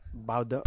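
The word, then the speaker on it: Baudot: Josh Parisian versus Alan, how do you say his name? Boydot? – Bado. – Okay, there you go.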